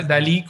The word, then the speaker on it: DALL-E: y se pronuncia DALL-E como si fuera el artista.